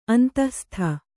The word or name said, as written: ಅಂತಃಸ್ಥ